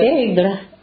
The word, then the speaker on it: Aigle: Com'è che si chiama la città? Egle. – Egle. – Aigle si scrive.